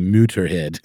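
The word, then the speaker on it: Motörhead: Like the band Motorhead, you know, has umlauts. If you pronounced it as you were supposed to, it would be Mutterhead.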